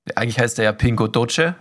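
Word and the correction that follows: Pingo Doce: so habe ich den genannt. Ja. Und eigentlich heißt er Pingo Dossi. Oh. Weil C und E schreibt man, spricht man anders aus, wie auch immer.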